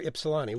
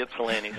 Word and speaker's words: Ypsilanti: Edward from Ann Arbor, Michigan, or Ypsilanti, which it says both here.